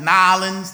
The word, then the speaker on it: New Orleans: New Orleans, Nolens, they just, you know, make it one word.